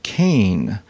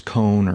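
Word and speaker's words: Koehn: He said, it's pronounced Kane. It's K-O-E-H-N, and I thought it was Cone or Cohen or something like that. But he said it's pronounced Kane, and nobody gets it right.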